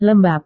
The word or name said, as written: lembab